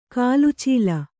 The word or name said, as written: ಕುಲಾಚಲ